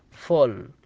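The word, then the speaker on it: full: الكلمة الثانية وهي فو فول، وهي تقريبا تعد من الحالات الشاذة أنها تنطق اليو بحرف الأو أو تكون مضمومة، لكن الأساس هي تكون بالفتحة.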